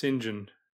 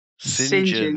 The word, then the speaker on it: St. John: Pronounced Sinjin. – Sinjin. – Mavis Sinjin.